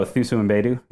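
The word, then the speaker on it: Thuso Mbedu: it's Thusu Mbedu. – Oh, Thusu Mbedu? – Yeah, yep.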